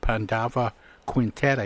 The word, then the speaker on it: Pandava Quintet: the Pandava Quintet.